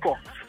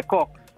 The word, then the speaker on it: Cox: Dinelson Cox. – What's it? Hello? – Yeah, Dinelson Cox. – Spell that for me, please. – D-E... – D-E... – N-E... – N-E... – L-S-O-N. – Cox? – Yeah, Cox. – All right. – C-O-X.